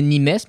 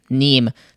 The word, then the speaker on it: Nîmes: On se retrouve à Nîmes en France parce qu'on a regardé comment ça se prononce Nîmes parce qu'il y a un S. Puis je pensais que c'était Nîmes, mais non, c'est Nîmes.